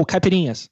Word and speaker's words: caipirinhas: Uh, oh, oh, caipirinhas.